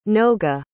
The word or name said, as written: noga